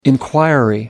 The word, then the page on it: enquiry: American English